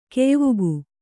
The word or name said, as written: ಕೆಯ್ವುಗು